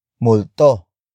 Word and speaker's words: multo: And that noun is multo. Multo. Multo. Multo is spelled as M-U-L-T-O.